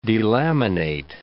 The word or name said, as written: delaminate